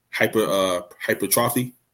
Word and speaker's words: hypertrophy: So they get the hypertrophy. That's how you say it.